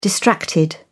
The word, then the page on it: distracted: British English